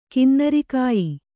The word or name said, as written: ಕಿನ್ನರಗೇಯ